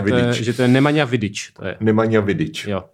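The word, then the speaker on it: Nemanja Vidić: říct, že od naší uh, pravidelné hostky a kamarádky Magdy Fajtové mám vyřídit, že uh, je špatně to, jak vyslovují jméno toho balkánského fotbalisty. Nemanja Vidič? Jo, jo, jo. Má být Nemanja Vidič? Něco takového, no.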